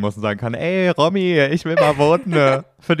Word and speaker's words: Romy: R-O-M-Y? – R-O-M-Y. – Das ist doch dann aber die Romy. – Nee, es wird Romy ausgesprochen. – Okay, ich würde es Romy nennen, um ehrlich zu sein. – Ja, ich auch, aber es wird Romy ausgesprochen, ja.